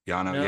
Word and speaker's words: Yano: I don't know if I'm saying his name right. Yano, yeah. It's kind of like experimental, kind of jazzy.